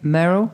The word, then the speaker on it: Merle: Remind me again how to say it again. Merle. Merle.